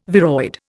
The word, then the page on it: viroid: Indian